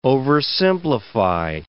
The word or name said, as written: oversimplify